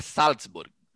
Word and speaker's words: Salzburg: Tem que falar, mas tem que falar com, como a pronúncia na língua original. – Ah, não. – Red é Bull Salzburg. Salzburg. O, o mais correto é Salzburg.